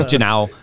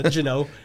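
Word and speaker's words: Juneau: And she spelled Juno, J-U-N-O, which is like, you would think that's how it's spelled, but it's J-U-N-E-A-U. – Oh, right. I was thinking. – Don't you know? – It's like Janow. – Yeah, Janow.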